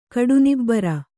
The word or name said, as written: ಕಡುನಿಬ್ಬರ